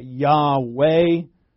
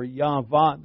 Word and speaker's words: Yahweh: So that's why you can get Yahweh, or Yahweh. That W is a vod, not a yod, a vav, and the vav can be a wuh or a vuh, either one.